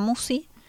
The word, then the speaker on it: Musci: Marisa Musi, M-U-S-C-I, se escribe mi apellido.